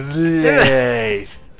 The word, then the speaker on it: Slade: Es que se pronuncia como Slade. Es más correcto como decirle Slade.